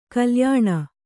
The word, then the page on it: ಕಲ್ಯಾಣಿ: kalyāṇi